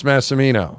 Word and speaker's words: Massimino: It's hard to pronounce Massimino.